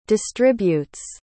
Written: distributes

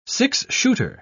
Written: six-shooter

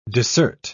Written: dissert

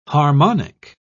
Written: harmonic

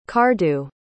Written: cardew

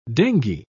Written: dengue

